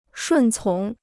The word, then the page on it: 顺从: shùn cóng